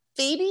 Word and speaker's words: Phoebe: Phoebe? Is that how you say her name? – Yeah. – Yeah.